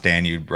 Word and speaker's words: Danube: All right, you pronounced Danube right today. The people, the keyboard warriors with the pitchforks got really mad last time.